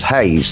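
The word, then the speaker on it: Heiz: And that's exactly what we're doing with a band called The Haze, T-H-E-H-E-I-Z. H-E-I-Z, they tell us it's pronounced Haze, so we're calling them The Haze.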